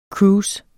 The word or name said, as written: cruise